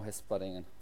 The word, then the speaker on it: Hesperingen: So, dann ging es für dich aber zu deinem jetzigen Verein nach der Saison zu, also es tut mir leid, wenn ich es falsch ausspreche, Hesperingen. Ja, genau, Hesperingen. Luxemburg, Hesperosch, Hesperingen, zum Swift, ja.